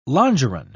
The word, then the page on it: longeron: British English